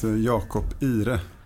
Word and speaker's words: Jakob Ihre: It's Jakob Ihre.